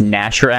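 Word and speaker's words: Gnasher: Flesh Ganasher drops the bamboo flower basket. – I think it's Nasher, actually. The G is silent.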